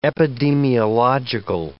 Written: epidemiological

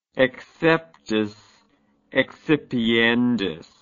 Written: exceptis excipiendis

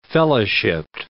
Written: fellowshiped

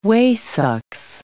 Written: waesucks

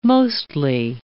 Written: mostly

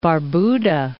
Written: Barbuda

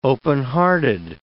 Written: openhearted

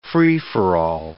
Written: free-for-all

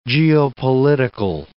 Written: geopolitical

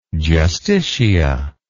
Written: justicia

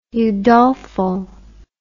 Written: Udolpho

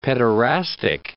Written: pederastic

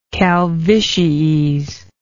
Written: calvities

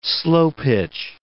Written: slow-pitch

